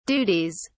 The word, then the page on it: duties: British English